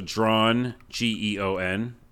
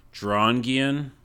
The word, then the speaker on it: Drawngeon: It's like drawn with dungeon. But I don't know how to say it. So Drawn, G-E-O-N. – Oh, okay. – Drawngeon.